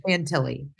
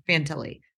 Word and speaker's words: Fantilli: But I do think what's interesting about the Bedard and Vitelli, how do you say his name? Why am I blanking? Fantilli. – Fantilli. That's right.